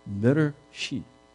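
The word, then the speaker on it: better sheep (בראשית): See, this word, Bereshit, I think we have it up there, how it's pronounced. In the beginning. better sheep.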